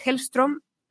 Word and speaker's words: Hellstrom: Hellstrom, sí, lo he dicho bien, que es una historia de Marvel, pero una Marvel mucho más oscura.